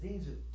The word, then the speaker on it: Sehnsucht: It doesn't look like Sehnsucht, does it? But Natalie was nice enough to write this out for me. This doesn't look like Sehnsucht, but that's how you say it, Sehnsucht.